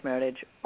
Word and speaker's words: Meritage: It is this blend word between merit and heritage. So you can let them know it's Meritage or not.